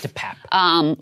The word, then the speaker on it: DePape: DePape. – Himself. – DePape.